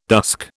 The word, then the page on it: dusk: British English